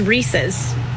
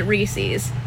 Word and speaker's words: Reese's: It is Reese's. It is a guy named Reese, and it is his. It's his. Reese's. And it's in the commercial. Reese's Puffs, Reese's Puffs. R-E-E-S-E-S.